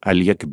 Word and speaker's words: Oleg: Oleg Blochin. Ja, Aliek Blochin. Ah, ja, so spricht man so wird richtig er ausgesprochen.